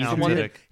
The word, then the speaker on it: Tudyk: How do you pronounce his last name? – He's Alan Tudyk.